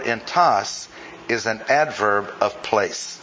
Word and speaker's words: entos: And that word is the word E-N-T-O-S, accent on the last syllable, entas. I'll stress it that way.